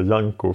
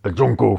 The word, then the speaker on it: Junko: Jag tror att det kommer från att du har lyssnat på engelsktalande källor som säger Janko för att engelska språket har skiftat alla vokaler. Men jag tror att på svenska och på japanska är det ett svenskt U. Junko Furuta, så är det korrekta japanska uttalet.